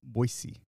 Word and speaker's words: Boise: I'm surprised you don't say it because I get called out not saying Boise. You gotta say Boise.